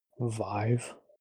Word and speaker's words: Vive: So this last week, they released Gunjack over to the Steam store for the HTC Vive launch. Vive? How is that pronounced? – Vive.